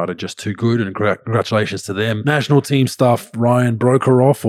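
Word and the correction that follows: Broekhoff: or Brokov, how it's actually pronounced, pro, and Isaac Humphries have pulled out.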